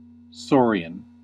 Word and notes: saurian: American English

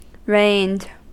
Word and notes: rained: American English

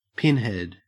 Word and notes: pinhead: Australian English

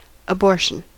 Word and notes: abortion: American English

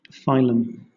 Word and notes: phylum: British English

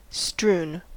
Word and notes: strewn: American English

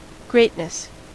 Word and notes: greatness: American English

